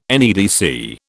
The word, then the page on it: NEDC: British English